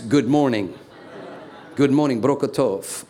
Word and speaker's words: Tob: The next thing it says, that when he is driven out, he goes to the land of Tob, T-O-B. It's in Hebrew, it's pronounced Tov. The B is a V, Tov.